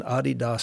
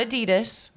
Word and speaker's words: Adidas: So we have, obviously, being an Adidas school, by the way, that's the correct pronunciation. – Not Adidas.